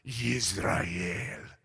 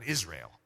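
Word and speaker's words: Yisrael (ישראל): Listen to how the Hebrew would pronounce it. Yisrael. Isn't that cool? That's so much better than Israel.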